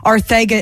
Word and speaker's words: Ortega: but it wasn't really the correct pronunciation because the Spanish pronunciation actually is Ortega. – Yes, and I read a story like 3 months ago that said that Ortega is the right pronunciation, so that's why I say it that way.